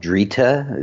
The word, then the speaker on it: Drita: This one, this producer produced, it's mostly a variety called Drita. It rhymes with Rita.